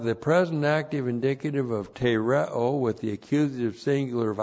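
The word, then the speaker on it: Tereo (τηρέω): Tereo. T, long E, R, E, long O. Tereo.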